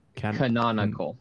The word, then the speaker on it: Canonical: Canonical. Canonical four syllables. Yeah, it's a tricky one.